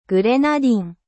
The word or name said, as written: grenade